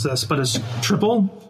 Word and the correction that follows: Tripel: Tripel. – Tripel.